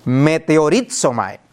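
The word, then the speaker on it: meteoritzomai (μετεωρίζομαι): Esa frase traduce la palabra griega meteoritzomai. Y la pronuncio a propósito. ¿A qué le suena? A un meteorito, a un meteoro.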